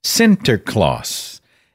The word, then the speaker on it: Sinterklaas: By the way, St. Nicholas is pronounced in Dutch as Sinterklaas.